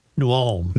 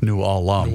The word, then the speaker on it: New Ulm: New Ulm. – New Ulm.